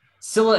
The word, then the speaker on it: Scylla: including Charon and the sea monsters Scylla and Charybdis. Pronunciation. Scylla and Charybdis.